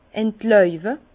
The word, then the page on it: entläube: Bas-Rhin; Herrlisheim